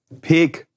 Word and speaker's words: Peek: Das heißt Peek und Kloppenburg. Explizit Peek. Ja. Peek. Und da wird auch sehr viel Wert drauf gelegt in der Werbung, dass das so ausgesprochen wird.